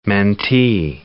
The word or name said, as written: mentee